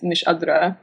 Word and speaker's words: Miss Hadra: It's, you know, it's a little awkward for just English speaking, but the proper pronunciation is Miss Hadra.